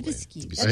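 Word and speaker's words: Rosée d'Hibiscus: So the beer I had last week that was really awesome, another Dieu du Ciel beer, is Rosé d'Hibiscus. That's my best attempt at a French pronunciation. – Probably d'Hibiscus.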